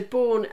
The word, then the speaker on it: Borne: So B-O-R-N-E is how it's spelled. So Bourne is this amazing charity, and they fund research into preventing premature birth.